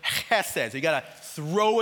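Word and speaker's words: chesed (חסד): And chesed, when you pronounce it, actually that H has kind of a guttural sound to it. So it's like chesed.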